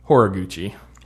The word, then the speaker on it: Horiguchi: Did I just say that wrong? Hold on. What's it? – I think it's Hiraguchi. – Horiguchi. – Horiguchi. – Yeah, it was Horiguchi.